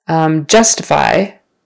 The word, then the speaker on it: justify: Justify. Justify. So first syllable stress.